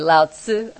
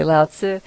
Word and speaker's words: Lao Tzu: Anyway, Lao Tzu. I know I'm saying that correctly. Lao Tzu.